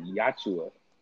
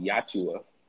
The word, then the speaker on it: Yautja: It's called Yachua. – That's it's called, huh? – It's called Yachua. – Spell it. Y-A-U-T-J-A. Mm. Yachua.